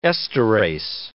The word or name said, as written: esterase